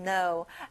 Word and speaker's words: know: 앞에 K 발음은 안 나요. Know.